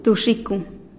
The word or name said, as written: duxikun